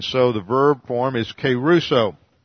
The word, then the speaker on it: kerusso: and there's the verb keruso. Looks like this in the Greek. K-E-R-U-S-S-O, long O.